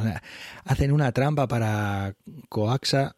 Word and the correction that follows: Caoxa: Eh, eh, eh, perdón, Caoxa, es que no lo he dicho bien,